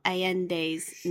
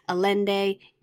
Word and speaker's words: Allende: Coming back in time to let you know that I made a mistake in the pronunciation of Isabel Allende's name. I unfortunately called her sort of anglicized Allende. It is Allende.